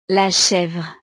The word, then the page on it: la chevre: frances